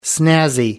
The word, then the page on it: snazzy: American English